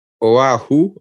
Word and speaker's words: Oahu: I read where Oahu, I hope I'm pronouncing it correctly, O-A-H-U. – Got it right. You said that.